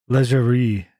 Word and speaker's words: Laguerre: I think you say his name, Laguerre. He's French. How do you say that? – I thought it was a soft G, but that's just me. – Like what? – Laguerre? – No, it's definitely not that. – Okay. – Yeah, it would be like Laguerre in French.